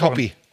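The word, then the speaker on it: Hoppe: Hoppi, den spricht man Hoppi.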